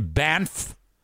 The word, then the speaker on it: Banff: Things to do in Banff. Which, that's how you say it. Banff. B-A-N-F-F.